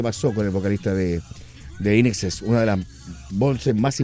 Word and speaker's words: INXS: O al pronunciarlo en inglés, INEXES, pero separado. No I-N-X-S, no. Es INEXES.